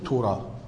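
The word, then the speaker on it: توراة (תורה): القسم الأول توراة، التوراة يعني بالعبري بيتنطق توراة، فيبقى دلته.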